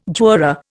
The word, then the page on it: juror: Indian